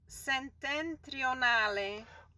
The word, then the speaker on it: Settentrionale: Africa Settentrionale. – Oh, Africa is no problem, but Settentrionale. – Yes, just watch how you roll your R's. – I will roll my R's, okay.